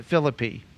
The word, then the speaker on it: Philippi: Here in Philippians chapter 1, or St. Paul writes to the church in Philippi, or as they say in West Virginia, Philippi. You know, there's a town spelled this way in West Virginia, but they pronounce it Philippi.